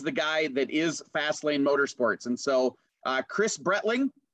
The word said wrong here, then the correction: Bretting: am I saying that right? It's Bretting. – Bretting. – I for whatever reason, I want to put an L in there all the time.